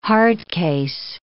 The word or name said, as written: hard case